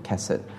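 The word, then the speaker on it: kesed (חסד): This is how I would pronounce it, kesed.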